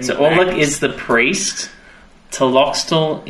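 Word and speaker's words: Tlotoxl: Taloxel. That's right. It starts with a T.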